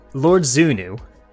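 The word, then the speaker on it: XVNV: So, Lord Zunu is how I pronounce it.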